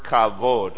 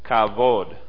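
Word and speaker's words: Kavod (כבוד): Kavod. C-H-A-B-O-D. Kavod. Actually, if you're going to say it correctly, you have to say the first part with a spit. Kavod. That's the way they would say it.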